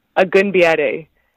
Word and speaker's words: Agunbiade: It's Agunbiade.